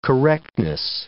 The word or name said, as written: correctness